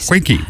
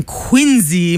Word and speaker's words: Quincy: Don't give sass to Quincy. – It's Quinky Market. – Oh, God, I hate you. – Quinky, that's where you're from, right? Quinky? – No, I am from Quincy, Massachusetts.